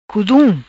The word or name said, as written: Cudum